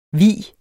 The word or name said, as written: vigen